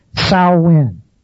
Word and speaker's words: Samhain: it's pretty safe bet that it's pronounced Samhain. The first part rhyming with cow, Samhain.